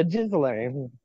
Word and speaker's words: Ghislaine: I think it's pronounced Ghislaine.